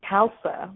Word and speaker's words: Kalsa: It's Kalsa, like salsa with a K.